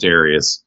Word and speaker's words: Darius: if you look at the Japanese pronunciation for it, then it is Darius,